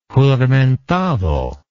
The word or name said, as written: juramentado